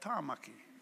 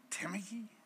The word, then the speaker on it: Tāmaki: I mean, a simple name like Tāmaki, Auckland, Tāmaki. It's not Tamaki.